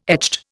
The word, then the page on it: echt: Indian